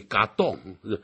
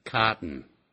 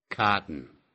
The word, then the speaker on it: cotton: 更不要学的日本式英语嘎洞，没有这样说法的。美式英语念成 cotton， cotton，啊，用鼻音出来。